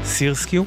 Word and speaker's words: SRSQ: מדובר באלבומה השני של מוזיקאית מופלאה מאזור המפרץ של ארצות הברית, קוראים לה סירסקיו, כותבים את שמה SRSQ, מבטאים את זה סירסקיו.